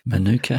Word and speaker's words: manuka: manuka honey, and um, and it uh, did I say it properly? Yes, good manuka honey.